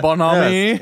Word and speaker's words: bonhomie: So all this clapping and false bonhomie, is that how you pronounce that? – Yeah, bonhomie. Yes.